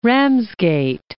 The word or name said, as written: Ramsgate